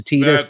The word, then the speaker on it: Teedus: So I renamed his name to T-E-E-D-U-S. So nobody can even say, John, that's not how you pronounce it, because I changed it. I changed it to Tetus. That's how it's pronounced.